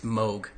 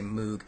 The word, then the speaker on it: Moog: I'm sorry, it's Moog. I used to always say Moog. And because that looks how it's supposed to be pronounced, but it's not.